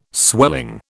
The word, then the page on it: swelling: British English